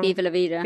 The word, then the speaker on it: Viva la Vida: Diva la vida, da diva, da, I always say it wrong. – Viva la vida.